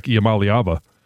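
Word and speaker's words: Iamaleava: Yeah, I mean, maybe the only one that would be comparison is how the former Tennessee quarterback, Iamaliava. – Sure. – Did I say that right? – Yeah, I think you nailed it.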